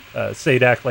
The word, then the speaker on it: Sadak: You know, he was like, "It's pronounced Sadak, like Sajak, except I don't get the gorgeous letter turning Vanna White."